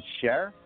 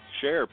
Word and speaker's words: Cher: They pronounce it like it's S-H-A-R-E. You ever hear people say Cher? – It is pronounced Cher. – It's Cher. – S-H-A-R-E. – Cher.